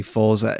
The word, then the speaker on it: Forza: Say Forza. It's supposed to be Forza. – Oh, is it? I didn't know that. – Yeah, because it's Italian, isn't it? – Yeah, yeah, but...